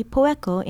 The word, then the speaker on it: poeko: Poeko, spelled P-O-E-K-O, means fluent.